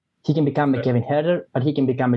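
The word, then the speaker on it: Huerter: He can become a Kevin Herder. Am I pronouncing it right? – Yeah.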